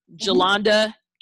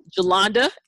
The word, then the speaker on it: Jalonda: It's just underneath of my name, Jolanda N. Thompson. Jolanda is spelled J-A-L-O-N-D-A.